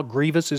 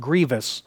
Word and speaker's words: grievous: And just because of my pet peeves, could we all just take a note of how grievous is spelled and how it's pronounced? It's pronounced grievous, not grievous.